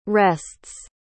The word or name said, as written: rests